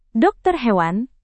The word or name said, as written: dokter hewan